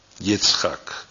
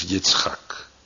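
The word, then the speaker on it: Yitzchak (יצחק): Dat is de Hebreeuwse uitspraak die bij ons een beetje verbasterd is tot Isaac of Isaak. Maar de Hebreeuwse werkwoordsvorm is Yitzchak.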